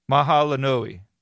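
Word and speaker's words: mahalo nui: And it took me about 5 takes to say it actually right. So, Mahalanoi.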